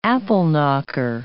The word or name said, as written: apple-knocker